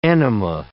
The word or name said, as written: enema